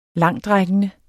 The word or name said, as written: langtrækkende